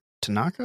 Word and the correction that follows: Tatanka: Am I saying that right? – Yeah. – Tatanka.